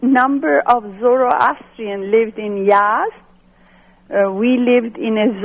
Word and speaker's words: Yazd: I grew up in Yazd, Iran. It's spelled Y-A-Z-D.